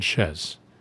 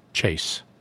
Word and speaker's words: chaise: So it was a chaise. They pronounced it chaise. The French would have said chaise. But this writer said chase, made the S unvocalized.